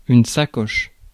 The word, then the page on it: purse: British English; /ˈpɜː(ɹ)s/; American English; [ˈpɜː(ɹ)s]